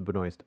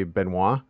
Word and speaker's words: Benoist: And am I pronouncing Melissa's last name correctly? – Yes. – Okay. Because, like, so in Canada, the Canadian in me wants to say Benoit or Benoit, you know, Benoit without the S.